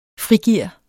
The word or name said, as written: frigear